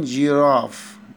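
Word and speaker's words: girafe: صورة رقم تمانية سيتين جيراف، الاتنين صح، حرف الار ينطق زي الراء او زي الغين في العربي.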